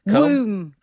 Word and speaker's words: comb: a silent B. – Comb, womb.